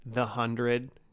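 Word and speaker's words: The 100: I feel like this is like one of those, like The Hundred. That's how you say it.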